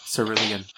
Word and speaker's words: Cerulean: Cerulean.